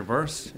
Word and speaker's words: Bearse: It's Peter Bearse. It's B-E-A-R-S-E.